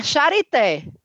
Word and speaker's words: Charité: E o outro na Charité. Me diga logo como é que fala. charité. Charité. – Ensina. Não, não é possível. Charité. É charité. Charité. Ah, parece francês, Mas né?